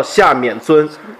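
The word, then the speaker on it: 夏丏尊: 是这样，然后这个因为本身是这样，本身我可能这样不对啊，但是就说是得更正张老师一个小小的一个小问题，就是这个夏老师呢，他这个借这个东西呢是一个多音字，他也读免，也叫夏免尊，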